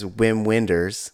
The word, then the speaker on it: Wim Wenders: Well, but like, so Nate, the guy, Wim Wenders, his name is Wim Wenders. – Right. So it's Wim Wenders. It's like so it's not Chekhov. It's a Wim Wenders.